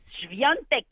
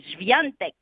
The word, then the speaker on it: Świątek: La polonaise Iga Świątek. Elle a dit qu'il fallait prononcer ça comme ça, la polonaise Świątek, qui a éliminé la russe Daria Kazatkina deux manches ce matin.